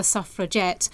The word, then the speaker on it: suffragette: And initially, the name was actually pronounced suffragette, you know, we're going to get the vote. – Yeah. – So with a hard G rather than the soft G.